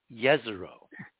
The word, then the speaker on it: Jezero: Yeah, except the J on Jezero is supposed to be pronounced, according to our sources, as a Y, Jezero.